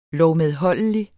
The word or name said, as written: lovmedholdelig